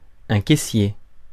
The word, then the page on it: caissier: France (Paris); [ɛ̃ ke.sje]